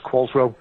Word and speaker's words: Qualtrough: It's of Manx origin. Qualtrough. It's pronounced Qualtro.